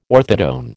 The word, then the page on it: orthotone: American English